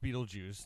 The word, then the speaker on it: Beetlejuice: It is pronounced Beetlejuice, the entire thing, but Beetlegeist, Alec Baldwin says Beetlegeist, because that's what it looks like it says, but it's actually Beetlejuice, but it doesn't...